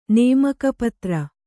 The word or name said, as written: ನೇಮಕಪತ್ರ